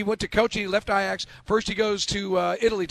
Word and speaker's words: Ajax: It looks like Ajax. They pronounce it Ajax in Holland.